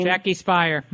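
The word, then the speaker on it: Jackie Speier: Oh, Jackie Spire, did I say her name? – Jackie Spire, yep.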